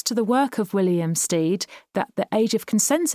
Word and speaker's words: Stead: Just interrupting the podcast here to clarify that we're talking about William Steed. I just mispronounced it as Stead.